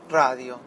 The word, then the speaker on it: Rdio: Lo pueden escuchar a través de radio, la aplicación radio, el servicio radio, que en realidad es RDIO, ¿sí? Radio, como quieren pronunciarlo,